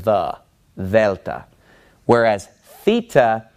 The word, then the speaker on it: theta: In math, you called this theta using an American pronunciation. But this letter in Greek is the letter theta, and it makes a th sound.